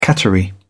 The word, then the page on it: cattery: British English